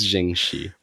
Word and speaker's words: Ching Shih: A woman whose name we are still figuring out how to pronounce, we believe, is Ching Shih. Spelled like Ching Shih, but we looked up the pronunciation of it, and we think it's Ching. We think it's Ching Shih. – We're fairly confident in Ching Shih.